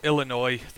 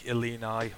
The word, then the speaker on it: Illini: I think this uh, these ones fall into the uh, the former category, but Illinois, the Fighting Illini or Illinois. Is it Fighting Illinois? Illini. Illini. Illini. Yeah. Illini.